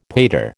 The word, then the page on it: pater: American English